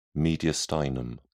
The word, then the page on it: mediastinum: British English